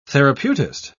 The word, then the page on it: therapeutist: British English